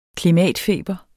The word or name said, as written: klimatfeber